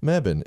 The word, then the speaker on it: Mebane: Mebane, or if you're from the north, it's Mebane. But, we don't say it like that here. Phonetically, it's Mebane in North Carolina.